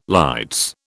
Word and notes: lights: British English

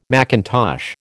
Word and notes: macintosh: American English

